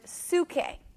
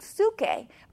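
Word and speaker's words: psuche (ψυχή): And that's where we get that word psyche from, although it's pronounced in the Greek psuche,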